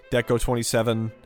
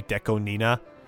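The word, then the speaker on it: DECO*27: There's a Deco Nina, Deco 27, however you want to say it. I think the official pronunciation is supposed to be Deco Nina.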